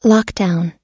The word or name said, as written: lockdown